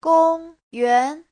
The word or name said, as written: gong1yuan2